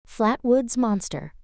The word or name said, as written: Flatwoods Monster